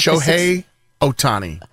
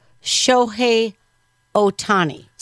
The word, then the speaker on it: Shohei Ohtani: Shohei Ohtani. – Shohei Ohtani.